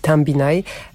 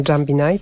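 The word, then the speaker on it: Jambinai: Przekorny tytuł nosi to nagranie, bo w ciszy to być może odpoczywamy po wysłuchaniu muzyki Tambinaj. Pisze się to przez J. Niektórzy czytają Jambinaj, tak z angielska.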